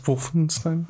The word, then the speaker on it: Wolfenstein: It's pronounced Wolfenstein.